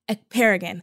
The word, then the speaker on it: Ekperigin: Guys, we have Naomi Ekperigin.